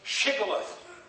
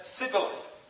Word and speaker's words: shibboleth: they asked them to say the word shibboleth, because the Ephraimites did not pronounce the H and said sibboleth instead.